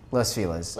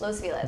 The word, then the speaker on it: Los Feliz: How do you pronounce it again? – Los Feliz. – Los Feliz. – But okay. Again, my parents are very white, so that's probably why they say it that way.